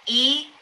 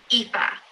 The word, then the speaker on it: Aoife: And it's pronounced Aoife. Aoife. It's spelled A-O-I-F-E.